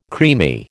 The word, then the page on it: creamy: American English